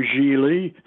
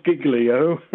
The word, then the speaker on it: Gigli: He taught me how to pronounce various things, like Beniamino Gigli, not Giglio, etc., etc.